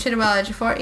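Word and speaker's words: Chiwetel Ejiofor: I always mispronounce his name, but Chichewa Ejiofor? – That's pretty close. Chichewa Ejiofor, yeah.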